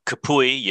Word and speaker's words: Kapui: So the short version is it's K-Yip, Kapui Yip, or my name is spelled K-A-P-U-I, and last name Yip.